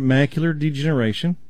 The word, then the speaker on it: macular degeneration: Macular degeneration.